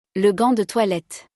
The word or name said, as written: Le gant de toilette